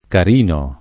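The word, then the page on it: carino: italiano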